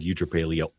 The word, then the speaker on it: eutrapelia: It's like a virtue called like eutrapelia.